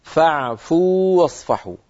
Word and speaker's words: فاعفوا واصفحوا: فاعفوا آخر حرف فيها واو ساكنة، واصفحوا أول حرف فيها واو متحركة، فالتقى في النطق واوان، واو ساكنة وواو متحركة، لكن الواو الأولى واو مدية، وحرف المد لا يدغم في الذي بعده. العرب لا تدغم الواو المدية، يعني لا يصح أن نقول فاعفوا واصفحوا، لا يصح أن نفعل ذلك، بل نقول فاعفوا واصفحوا، فاعفوا واصفحوا، ونعطي حرف المد حقه.